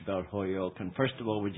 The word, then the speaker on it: Holyoke: Well, yes, but you're from New York, so you're giving it a New York pronunciation. – Oh, okay. – Holyoke. – Holyoke.